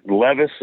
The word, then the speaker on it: Levis: Okay, so the other thing is that scares me is that their quarterback, is it Levis? Is that how you say it? – That is correct.